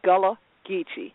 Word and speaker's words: Gullah Geechee: First of all, I want all 100 children with a Yeti this year to know how to pronounce this, you think. It's Gullah. Geechee, Gullah Geechee Nation,